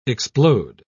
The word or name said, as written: explode